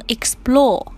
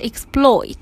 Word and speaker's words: exploit: 大家在读这个单词的时候，注意里边有一个这个 a 的音哈，不要把它读成 explore 哈，应该是 exploit。